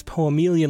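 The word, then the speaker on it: Poemeleon: I always stumble on what to say, how to pronounce that, but it's Poemelion, like chameleon, I believe.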